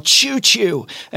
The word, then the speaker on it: Chew Chew: I'd like to talk about a product called Choo Choo, and it's spelled C-H-E-W-C-H-E-W.